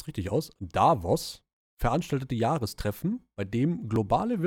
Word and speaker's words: Davos: Ich glaube, ausgesprochen wird es aber Davos und das ist das Weltwirtschaftsforum.